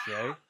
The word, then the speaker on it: Shai: It's pronounced Shai.